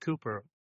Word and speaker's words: Cowper: whose name is spelt William Cowper. – Right. – And but pronounced Cooper.